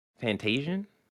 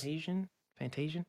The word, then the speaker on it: Fantasian: We have Fantasian. Did I say that right? – Yes. – Fantasian.